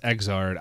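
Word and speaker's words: Xrd: Meanwhile, all of these Arc System games, ever since that Guilty Gear Zerd, I think was what it was called. – I think it's pronounced Exard.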